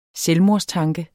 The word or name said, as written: selvmordstanke